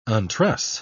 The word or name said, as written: untruss